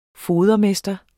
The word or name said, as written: fodermester